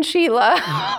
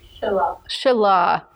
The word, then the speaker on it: Shelah: And then I want to call this one Sheila. Over and over is totally not Sheila. How do you say it? – Shelah.